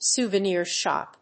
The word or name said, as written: souvenir shop